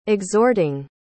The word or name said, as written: exhorting